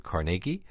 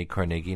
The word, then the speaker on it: Carnegie: And it's important to say Carnegie, not Carnegie.